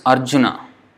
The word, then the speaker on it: Arjuna: So, a good example would be, like for example, we say Arjuna. In Sanskrit, it's Arjuna. So, Arjuna. No, the last A is also pronounced. So, Arjuna. But in Hindi, it will be Arjun. The A is not pronounced.